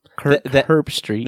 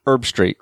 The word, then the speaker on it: Herbstreit: No. The H is silent. It's just like, you don't say, I bought some herbs at the store today. – Herbs. It's not Herbstreit. – You say Kirk Herbstreit.